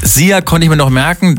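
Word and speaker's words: Szia: Wird einfach ausgesprochen wie unser Energy Star. Sia.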